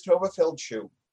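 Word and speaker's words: Tovah Feldshuh: I'd been mispronouncing her name for 30 years. Her name apparently is Tova Feldschuh. – Wow, okay. – Who knew? – It sounds so much less Yiddish.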